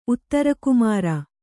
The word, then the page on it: ಉತ್ತರಕುಮಾರ: uttarakumāra